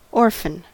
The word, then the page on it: orphan: American English